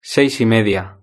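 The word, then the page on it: seis y media: Spanish (Spain)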